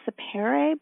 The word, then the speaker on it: sapere: But you brought forth a topic that I had not heard of before, and it was, am I pronouncing this correctly, Sapir? – I think it's pronounced sapere, but I've only read it myself, but I think that's how you pronounce it. It's from Latin.